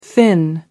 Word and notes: finn: American English

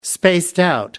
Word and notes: spaced-out: American English

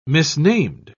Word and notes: misnamed: British English

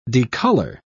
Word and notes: decolour: British English

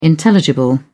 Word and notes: intelligible: British English

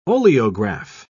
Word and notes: oleograph: British English

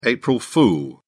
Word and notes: april fool: British English

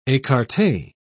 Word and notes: ecarte: British English

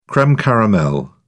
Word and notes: creme caramel: British English

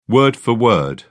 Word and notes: word for word: British English

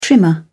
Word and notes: trimmer: British English

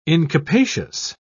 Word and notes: incapacious: British English

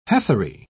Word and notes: heathery: British English

